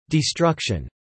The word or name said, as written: destruction